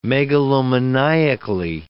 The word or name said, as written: megalomaniacally